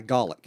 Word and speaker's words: Gaelic: Gaelic.